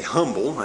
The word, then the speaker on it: humble: Now, it's when we are humble, or humble, I'm not sure exactly how y'all pronounce that. I say humble, I sound out the H, but It's when we're humble that the Lord is pleased to use us in His service.